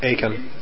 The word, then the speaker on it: Aiken: Aiken. – Aiken. – Aiken. – It's Aiken like bacon. – Rhymes with bacon.